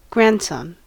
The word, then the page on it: grandson: American English